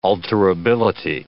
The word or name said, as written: alterability